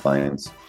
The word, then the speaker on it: Fiennes: It should be Fiennes because we both took German and it's IE. – Yeah, but I think it's pronounced Fiennes. – All right, well, he says it wrong. It's Fiennes.